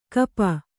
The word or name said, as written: ಕಪ